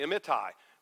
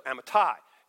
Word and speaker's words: Amittai: And we would probably, as in our culture, we would say Amittai. We would tend to accent the middle. Well, they always accent the Hebrew. It's on the back syllable. It's not Habakkuk, it's Habakkuk. And so Amittai, you know, it puts the emphasis there.